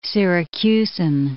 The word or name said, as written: Syracusan